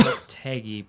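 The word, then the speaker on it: Lopetegui: is it Loptegui? – I think it's pronounced Loptegui. – Loptegui, that makes more sense.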